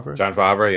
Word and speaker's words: Favreau: It's going to be, it's John how do you even say his name? Favreau? – John Favreau, yeah.